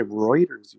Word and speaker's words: Reuters: Like R-E-U-T-E-R? – Yeah. – Oh, okay. I pronounce that router. Okay, Reuters you pronounce it.